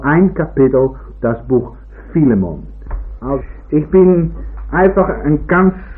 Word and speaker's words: Philemon: So Philemon, sagt irgendjemand hinten, und ich denke, das ist wohl die richtige Aussprache davon.